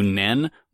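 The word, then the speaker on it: Nen (念): So the Japanese form actually preserves the pronunciation of Nian to Nen much more closely.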